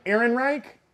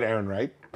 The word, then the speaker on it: Ehrenreich: In an interview with Up Rocks, Alden Ehrenreich, I said that right? – Yeah. – Okay, good. – You said that Ehrenreich.